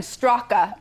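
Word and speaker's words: Straka: It's spelled Brandon Strocka, but pronounced Strock.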